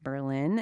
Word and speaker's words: Berlin: while it is spelled like the German capital, it's not pronounced Berlin. It's pronounced Berlin. You know, emphasis on the first syllable. It's very, very subtle. Berlin, not Berlin.